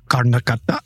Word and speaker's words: Karnataka: In the quiet villages of Karnataka, where ancient traditions and modern life intertwine. – Wait, why did you change your accent just for that one? – That's how you say it. Karnakata.